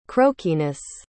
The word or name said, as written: croakiness